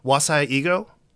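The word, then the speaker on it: Wasai Ego (和製英語): I think in my piece, I point out that it's an example of Bob, you can tell me if I'm pronouncing this correctly. Wasai Ego? – Is that sounds correct.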